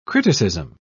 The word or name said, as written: criticism